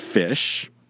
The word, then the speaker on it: ghoti: P-H-I-S-H? – No. No, like the animal. Here it is. G-H-O-T-I. – And that is... – that is fish. – Pronounced fish? – It is pronounced fish. – Okay. – And the reason for that is that the G-H makes an F sound, such as in rough, R-O-U-G-H. – Uh-huh. – The O makes an I sound like women. So that gives you the fi. – Uh-huh. – The T-I makes a sh sound like in nation. Oh. So G-H-O-T-I can be pronounced fish.